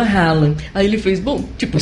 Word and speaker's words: Haaland: Haaland. – É, ele não parece. – Que a pronúncia correta é Haaland.